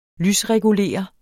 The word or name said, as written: lysregulere